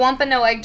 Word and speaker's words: Wampanoag: Yeah, no, I heard that. You said it right. – I didn't want to mess it up. I was like, that's disrespectful. The Wampanoag tribe used the wetland for its fertile hunting ground, and they also used it as a sacred burial ground.